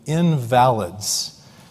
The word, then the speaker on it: invalids: And in verse 3, you read, in these colonnades, these walkways, lay a multitude of invalids. Now, you might have thought that I pronounced that word incorrectly, because we wouldn't typically use that word and say it the way that I did.